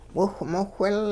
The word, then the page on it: wiwhmowhil: Hupa